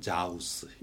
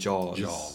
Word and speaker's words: jaws: 그런 단어를 보면 상당히 많은 한국 사람들이 그냥 무심코 자우스 이렇게 읽는 사람들이 많아요. 자우스. – 사실 AW는 AW랑 AU 둘 다 비슷한데 그러니까 우리 지난번 그 표기법에 대해서 얘기했을 때 영어로 어라고 발음하는 거는 거의 다 한국어로 옮기면은 오 되는 거. 그래서 jaws도 마찬가지잖아요. 원래 발음은 jaws. Joe's는 아니에요. Joe라고 하면은 이름 Joe라고 생각해요. So Joe 내 가게 이렇게 Joe's라고 하면은 J-O-E.